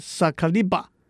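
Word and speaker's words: 盛り場: 日文发音是萨卡利巴。日治时代在台南市新建的康乐市场是小吃摊聚集的地方，也叫做萨卡利巴。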